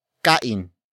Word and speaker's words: kain: Kumain consists of the root word kain. Kain. Kain. Which is spelled as K-A-I-N.